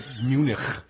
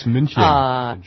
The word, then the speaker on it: München: Yeah, like people say Munich. There's no such place as Munich. It's München.